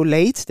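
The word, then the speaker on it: Leite: William Pacho, Diogo Late, der wird übrigens late ausgesprochen, wie wenn ein Sachse wie ich äh, das äh, englische Wort für spät Late ausspricht.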